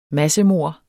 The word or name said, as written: massemord